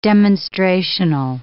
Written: demonstrational

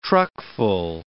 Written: truckful

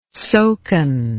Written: soken

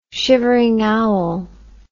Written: shivering owl

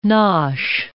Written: nosh

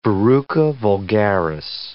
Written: verruca vulgaris

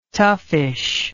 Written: toughish

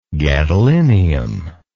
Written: gadolinic

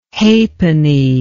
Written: hapenny

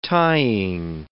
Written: tying

